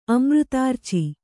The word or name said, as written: ಅಮೃತಾರ್ಚಿ